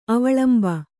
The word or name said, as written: ಅವಲಂಬಿ